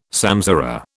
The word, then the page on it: samsara: British English